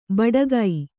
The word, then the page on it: ಬಡಗೈ: baḍagai